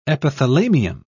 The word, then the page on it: epithalamium: British English